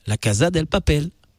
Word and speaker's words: La Casa del Papel: La Casa del Papel, c'est comme ça que ça se prononce.